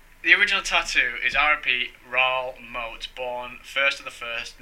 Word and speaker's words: Raoul Moat: It's Raoul Moat.